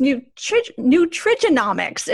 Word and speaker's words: nutrigenomics: It's nutrigenomics. Is that correct? – You pronounced it perfectly right.